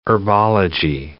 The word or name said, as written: herbology